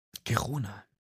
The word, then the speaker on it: Girona: Ja, G-E-R-O-N-A. – Ach, so ähnlich wie Verona. – Ja, genau. Das ist Verona mit dem G. – Mhm. Girona. Das ist jetzt auch gemein. Ich bin wegen Verona bei einer Stadt. Girona könnte aber auch ein Gewürz sein und deswegen traue ich mich jetzt, Gewürz zu sagen.